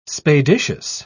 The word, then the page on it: spadiceous: British English